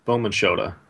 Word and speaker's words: BowmanShoota: No one pronounces it right, but that's okay. – Okay. What is it? BowmanShoota? – BowmanShoota. It's Shota? Japanese, but it's okay. Everyone says Shoota.